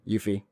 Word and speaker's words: Yuffie: But, of course, I think the biggest thing is Yuffie. Yuffie? Yuffie? How do you say it? – Yuffie. – Yeah, oh, Yuffie.